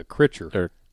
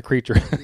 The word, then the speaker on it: Kreischer: The Critcher. Not the creature. – Yeah. – The Critcher. – K-R-E-I-S-C-H-E-R. Critcher.